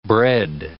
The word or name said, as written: bred